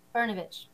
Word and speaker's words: Brnovich: Brnovich.